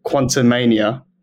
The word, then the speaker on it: Quantumania: Furthermore, the Disney shows anticipated with enthusiasm proved to be a mixed bag, and recent disappointments like Ant-Man, the Wasp, Quantumania, is that how you pronounce it? – Yeah, it's a disastrous name.